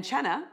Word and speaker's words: cena: And cena actually means dinner or supper in Italian. – I learned that. That's cool. I didn't know that. – Well, everyone says cena. It's spelled C-E-N-A, but it's pronounced cena.